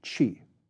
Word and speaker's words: Qi: These political and military conditions begin to change in the mid-7th century BC, most notably in the northern state of Qi, Qi, pronounced Qi.